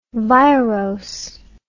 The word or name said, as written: virose